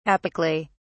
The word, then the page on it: apically: British English